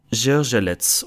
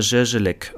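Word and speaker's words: Zgorzelec: Ich glaube, es wird ungefähr Zierzelec oder Zierzelek ausgesprochen.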